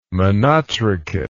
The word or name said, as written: monotrichate